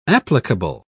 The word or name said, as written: applicable